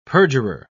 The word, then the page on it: perjurer: British English